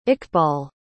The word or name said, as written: iqbal